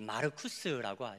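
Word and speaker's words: Marcus: 로마식 이름인데 이제 망치라는 이름을 가진 로마어로 이제 발음하면 이제 마르쿠스라고 하죠.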